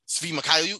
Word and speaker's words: Svi Mykhailiuk: 1 year play option for Svi Mykhailiuk.